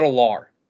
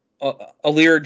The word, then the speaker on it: Allar: I think that this team, even though they're at 9.5 wins, I think that if Drew Allier, man, I heard the correct pronunciation of his name, and it's not Allar. That is not right. Oh my gosh, it doesn't matter. Drew Allar? – Allar, that might be it.